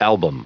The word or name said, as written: album